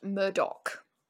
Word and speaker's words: Murdaugh: So, today we are talking about the case of the Murdaugh family, which is really funny because it's spelled Murdaugh. And so if you were to just see that, like, really quickly, you might think it says murder. – Yeah. – But it's pronounced Murdaugh.